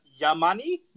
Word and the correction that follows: Yamane: No, go ahead, Jake, take it. – Hold on, I'm looking, oh, Kyohei Yamane. – Yamane. – Kyohei.